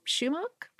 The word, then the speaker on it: sumac: Well, sumac, sometimes pronounced shumac, I think, is a type of spice or seasoning.